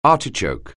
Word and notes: artichoke: anglais

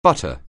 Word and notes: butter: anglais

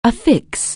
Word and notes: affix: anglais

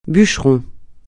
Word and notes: bûcheron: francais

